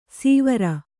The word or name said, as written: ಸೀವರ